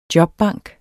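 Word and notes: jobbank: Danish

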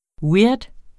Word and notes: weird: ˈwiɐ̯d; ˈwiːɐ̯d

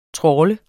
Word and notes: trawle: [ ˈtʁɒːlə ]; [ ˈtʁɔːlə ]

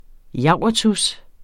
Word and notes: javertus: ˈjɑwˀʌtus

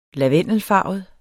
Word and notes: lavendelfarvet: [ -ˌfɑˀvəð ]; [ -ˌfɑːvəð ]

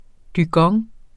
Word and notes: dygong: dyˈgʌŋ; ˈdygʌŋ

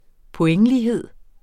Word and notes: pointlighed: poˈεŋˌliːˌheðˀ

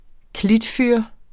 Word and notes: klitfyr: -ˌfyɐ̯; -ˌfyɐ̯ˀ

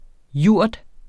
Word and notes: jurt: ˈjuɐ̯ˀd

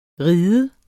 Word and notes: ride: verbum

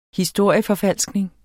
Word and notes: historieforfalskning: dansk

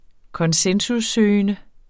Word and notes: konsensussøgende: [ -ˌsøːjənə ]; [ -ˌsøˀjənə ]

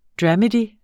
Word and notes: dramedy: [ ˈdɹamədi ]; [ ˈdɹɑmədi ]

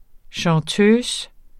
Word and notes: chanteuse: ɕɑ̃ˈtøːs; -ˈtøːsə; -ˈtœːs

